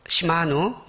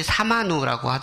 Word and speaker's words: 시마누: 시마누 월에 이게 발음은 시마누인데 사람들이 사마누라고 하더라고요.